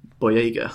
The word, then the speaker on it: Boyega: Abrams, and it stars Harrison Ford, Mark Hamill, I guess, Carrie Fisher, Adam Driver, Daisy Ridley, and John Boyega, I believe is how you pronounce that last name.